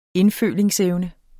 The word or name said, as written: indfølingsevne